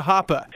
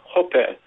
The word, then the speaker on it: Hoppe: Well, because it's H-O-P-P-E, so you don't automatically go to Hoppe. – Right, exactly. It is a German last name, so it's technically pronounced more like Hoppe, but who can say that, yeah.